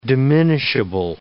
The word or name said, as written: diminishable